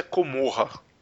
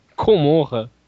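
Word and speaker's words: Commorragh: Hoje eles se organizam numa cidade que fica em uma parte da Webway, uma cidade-estado chamada O jeito que se escreve é diferente, mas a pronúncia é Comorra. – Comorra. – Então, lembra mesmo Gomorra, sabe?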